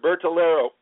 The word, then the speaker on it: Bertolero: I'm not butchering your last name, am I? – No, sir, Bertolero.